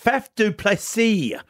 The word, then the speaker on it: Faf du Plessis: And I tell you, I like Faf du Plessis.